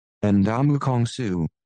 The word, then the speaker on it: Ndamukong Suh: Ndamukong Suh. – There we go. You please. Yeah, I couldn't even do it. I was going to have to do it again. Ndamukong Suh.